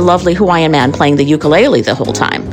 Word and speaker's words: ukulele: Ukulele. That's how they say it in Hawaii.